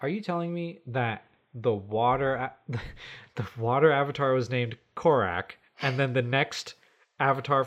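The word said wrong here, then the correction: Kuruk: Kurok. – Kurok. – Kurok. Sorry. – Kurok. Yeah, you've been saying Kurok. – I've just been saying it bad. Sorry. Kurok. It's K-U-R-U-K.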